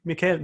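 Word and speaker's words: Michel: I was reading this book called American Terrorist by Dan Helbeck and Lou Michael, I don't know how you pronounce it. There's no A in there, right? – Oh, A. Michelle. – Michelle.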